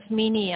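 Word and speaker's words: Menie: It's Mini, I'll spell it to you, it's very unusual. It's M-E-N-I-E. – Yep, and your surname? – And then Theron, T-H-E-R-O-N.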